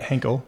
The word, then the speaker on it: Henkel: I tried to amass, didn't get into the round, but you guys now have announced a Series A with, I think, Henkel, or I don't know if I'm saying that right. – Henkel. – Which is a much bigger soap company.